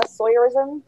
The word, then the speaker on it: Sawyerism: As for where you can find me, my TikTok and my Instagram are Sawyerism. That's my name, except put, this is going to sound horrible, but just put my name into the word voyeurism, and that's how you spell it.